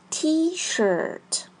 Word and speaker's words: T-shirt: T-shirt，发音时注意 i r 发 er。 T-shirt，书写时别忘了 T 和 shirt 之间的中横线。